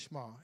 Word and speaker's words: Shema: You might have heard that as the Shema. It's pronounced Shema, and it means hear.